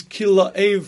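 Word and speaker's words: Kilav: The proper pronunciation of David's son's name is Kilaev from Kilaav, like from the father, because, to be honest with you, he's not one of the famous people in Chazal.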